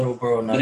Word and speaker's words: Joe Burrow: Joe Burrow? – Joe Burrow.